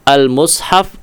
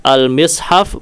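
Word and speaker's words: المصحف: Maka ini, ya, mimnya kalau dalam bahasa Arab ini dikatakan muthallathu al-mim. Ya, mimnya muthallath, yakni boleh diucapkan dengan dhammah, dengan kasrah, dengan fathah. al-mushaf, al-mishaf maupun al-mashaf. Semuanya sahih.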